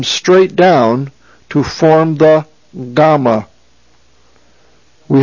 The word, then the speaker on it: gamma: Again, it's pronounced gamma. It is the G sound, as in got, g.